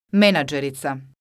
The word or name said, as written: menadzerica